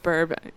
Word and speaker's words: BRB: It's pronounced burb. It's pronounced burb.